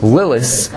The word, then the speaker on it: Lilith: He wanted to bring up Sam, which is the name of the Satan, Samech Mem, which is a gematria of 100, and Lilith, Lilith, I'm not saying it with the th, because that's how it's supposed to be pronounced.